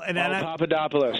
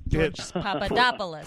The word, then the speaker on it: Papadopoulos: Papadopoulos.